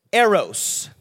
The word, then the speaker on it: eros: Okay, the first one is eros. Everybody say eros.